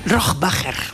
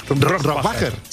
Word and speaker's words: Rohrbacher: Bueno, ¿cómo se pronuncia Alba Rohrbacher Cochet? – Rohrbacher. – Rohrbacher. – Sí.